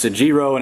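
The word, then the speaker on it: egiro (ἐγείρω): The word raised in the Greek is egiro. I'm not going to spell it for you, but it's egiro, and it means to awaken, to rise, to be born.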